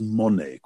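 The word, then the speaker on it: mone (μονή): which in Greek is mone, quite an easy little word, mu, omicron, nu, eta, mone, M-O-N, long E.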